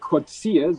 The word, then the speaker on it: Coetzee: Das ist Dr. Angelique Kotzee, so wird sie ausgesprochen, geschrieben wird sie Coetzee.